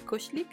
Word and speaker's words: koselig: Koslik. no sé si lo estoy pronunciando bien, pero se escribe Koselig con K.